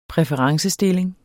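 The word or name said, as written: præferencestilling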